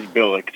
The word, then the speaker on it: Bilek: It is Bilek, just like Bilek. The old Ravens coach Brian.